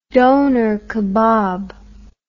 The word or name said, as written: doner kebab